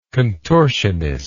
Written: contortionistic

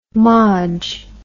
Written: modge

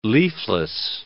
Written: leafless

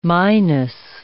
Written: Minos

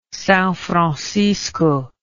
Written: Sao Francisco

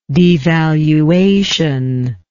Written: devaluationist

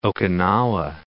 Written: Okinawa

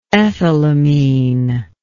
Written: ethylamine

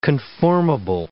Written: conformable

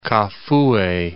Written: Kafue

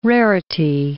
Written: rarity

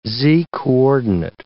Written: z-coordinate

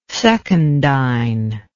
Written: secundine

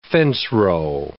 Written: fencerow

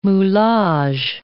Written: moulage

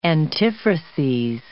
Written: antiphrases

